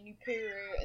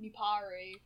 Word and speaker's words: Nuparu: It was like, I used to say Nupuru instead of Nuparu.